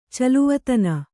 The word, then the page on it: ಚೆಲ್ವತನ: celvatana